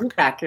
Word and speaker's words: Shugak: As anybody who reads the Kate Shugak, am I saying that right? Or Shugak. – Shugak is exactly Shugak. – Right.